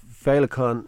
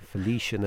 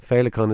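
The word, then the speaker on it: féileacán: They were calling it Felician and everything. Felicon is obviously the word for butterfly, and that's what we see in Irish mythology.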